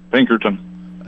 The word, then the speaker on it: Pinkerton: Pinkerton. – Spell it. – P-I-N-K-E-R-T-O-N.